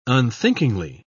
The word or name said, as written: unthinkingly